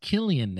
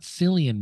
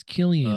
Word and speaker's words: Cillian: Best actor was I know it's pronounced Killian now. It's not Cillian Murphy. It's Killian Murphy. – Oh, it's a hard C.